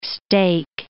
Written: stake

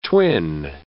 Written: twin

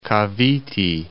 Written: Cavite

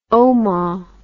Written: ouma

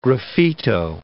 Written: graffito

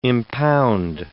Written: impound

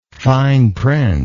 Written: fine print

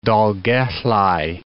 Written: Dolgellau

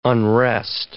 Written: unrest

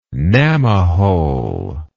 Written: gnamma hole

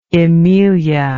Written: emelia

